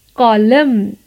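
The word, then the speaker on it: column: कॉलम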